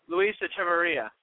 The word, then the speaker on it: Luis Echeverria: Luis Echeverria.